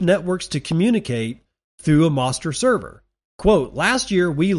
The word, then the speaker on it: Mostr: Now the Moster Bridge, M-O-S-T-R, like Noster, but spelt with an M.